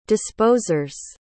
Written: disposers

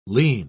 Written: lean

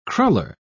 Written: cruller

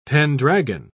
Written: pendragon

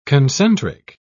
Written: concentric

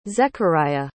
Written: zechariah

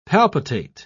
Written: palpitate

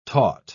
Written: taught

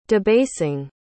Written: debasing

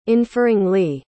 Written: inferringly

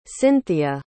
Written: cynthia

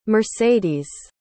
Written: mercedes

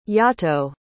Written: yahto

